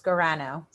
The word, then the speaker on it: Scorrano: And I'm gonna say your last name wrong, so I want you to say it so I don't pronounce it wrong, please. – Okay, I was gonna say give it a try, but it's Scorrano.